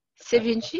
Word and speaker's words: Sevinj: Sevinj, it's correct.